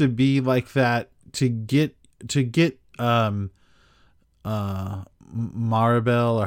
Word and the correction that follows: Mirabel: or however you say her name? – Maribel. Mirabel. – Mirabel. – Um, Mirabel.